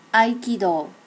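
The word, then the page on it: aikido: native Japanese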